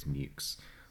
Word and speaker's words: Meux: 5 years later, Richard Mewks, it's spelled M-E-U-X, so I keep wanting to say Mo, but it's Mewks,